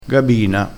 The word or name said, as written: gabina